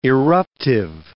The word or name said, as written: irruptive